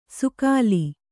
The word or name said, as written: ಸೂಗಲಿ